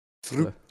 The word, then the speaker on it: through: Euh, je crois que tu es spécialiste, elle raconte de, de de l'anglais, tu peux nous éclairer True. True. Ok, donc true. True, comme, euh, comme le The Last of Us, quoi.